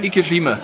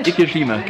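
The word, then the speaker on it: ikejime: Wie spricht man die aus? Weil das konnte ich mir gerade nicht merken. – Ikeshime. – Wie nochmal? Ikeshime? – Ikeshime, genau. – Ikeshime, Ikeshime. – Genau, richtig. Das ist diese Schlachtmethode, die halt sehr, sehr schonend ist.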